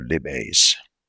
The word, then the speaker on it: lipase: Protease, carbohydrates, and lipase. Is it lipase or lipase? Lipase. I was off.